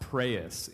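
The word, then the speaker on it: praeus (πραΰς): it's pronounced praeus in Greek.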